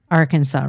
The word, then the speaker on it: Arkansas: So every time I see it, I know in connection to Tulsa, it's pronounced Arkansas River.